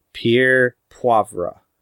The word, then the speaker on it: Pierre Poivre: And it was possibly written about 18th century French horticulturist Pierre Poivre, which I know I said that right because I wrote down the pronunciation for it.